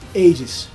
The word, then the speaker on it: Hades: Como é que fala esse nome? – Hades. – Hades. Então, Hades.